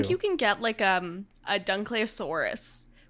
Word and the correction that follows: Dunkleosteus: which looks... Dunkleosteus. I pronounced it wrong.